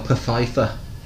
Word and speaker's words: Pfeiffer: There's one that I've not seen, and it was quite a biggie, The Russia House, with Michelle Pfeiffer.